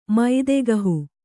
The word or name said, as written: ಮೈದೆಗಹು